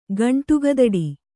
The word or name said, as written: ಗಂಟುಗದಡಿ